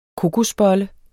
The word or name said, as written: kokosbolle